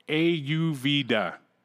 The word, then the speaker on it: Ayurveda: Missy says, for Keith, it's pronounced Ayurveda. Ayurveda.